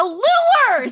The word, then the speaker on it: lure: A lure! Say lure! It's two syllables.